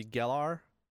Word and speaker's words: Galar: It's set in the, I think it's called, or pronounced the Galar region. G-A-L-A-R.